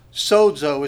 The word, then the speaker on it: sozo: The way you would spell it is S-O-Z-O, sozo. Actually sounds like it has a D in it, but it's probably would be spelled in English this way.